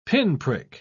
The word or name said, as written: pinprick